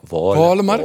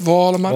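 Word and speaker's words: Wålemark: Maar dat uh, gaan we dat, is, dat is zo moet je uitspreken. – Wolemark. Wolemark. Ja? Spreek jij hem ook zo uit? Wolemark? Wolemark.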